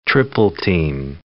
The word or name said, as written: triple-team